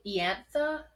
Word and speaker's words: Ianthe: And I'm probably pronouncing her name wrong, but Ianth. – I say Iantha. – Okay, I did Google it and it said Ianthe, so I think you do pronounce the E in some way.